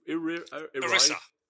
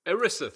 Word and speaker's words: ERISA: I know I said it wrong. – ERISA. – ERISA. Thank you. Thank you. it doesn't spell that way to my thinking, but ERISA, which is the employment.